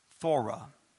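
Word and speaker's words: thura: The word door, I'm going to spell it for you, is T-H-U-R-A. T-H-U-R-A. Thora. Okay? That's 2374. Thora. 2374.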